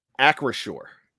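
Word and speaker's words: Acrisure: Make sure I'm correct. AcroShore. – As far as I know, that's the right pronunciation.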